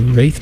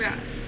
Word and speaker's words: Wraith: You're about to listen to Wraith Pinned to the Mist. – Wrath. – I think it's Wraith. – I think it's Wrath. – Wrath is spelled differently. That's definitely Wraith.